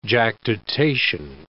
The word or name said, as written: jactitation